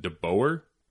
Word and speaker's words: DeBoer: Is it DeBoer? – Yeah, so if you want the traditional Dutch or the American version, it doesn't really matter. But most people say DeBoer, like the hog.